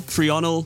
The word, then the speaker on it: threonyl: It's the technical chemical name for the protein chitin, and this is how you say the start of it. Methionyl, threonyl, threonyl, glutenamine, leginyl.